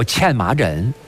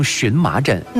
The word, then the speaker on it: 荨麻疹: 这最常见到的一个皮肤病，把它叫茜麻疹。今天大家也把它读成荨麻疹。